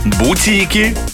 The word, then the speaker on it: бутики: Как нужно произносить слова. Итак, просека, пурпур, бутики, пуловер и манты, когда будете заказывать, именно так произносите.